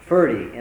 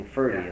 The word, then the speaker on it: Forde: Ferdy, and it's spelled F-O-R-D-E, and like 40, only Ferdy is the pronunciation.